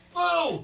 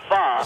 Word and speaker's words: pho: So the correct pronunciation is foo. – Foo. – No pho.